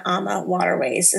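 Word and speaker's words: AmaWaterways: This was an adventure by Disney cruise on Ama Waterways is what we went on, right? That's the correct way to say it.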